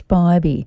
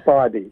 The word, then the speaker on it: Spivey: Spidey, sorry, Spibby. – It's, uh, it's Spivey. Oh, it's Spivey. Yeah, I got it wrong too, but um, it, yeah, it sort of rem- reminds with, you know, rhymes with Spidey as in spidey sense.